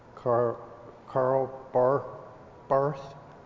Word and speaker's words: Barth: Karl Barth in Joy by the Beverly Elaine Ernst. – You got the German pronunciations. Yes, you got the hard one.